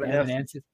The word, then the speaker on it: Evanescence: Evanescence.